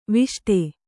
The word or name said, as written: ವಿಷ್ಟಿ